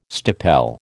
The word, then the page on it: stipel: American English